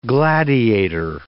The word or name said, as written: gladiator